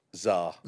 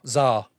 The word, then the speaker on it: Tsar: In fact, you're definitely going to have to correct me because I don't know how to say the first word. Tsar? – Tsar. – Tsar. Why have they put a fucking T at the start then? Right, we go with Tsar.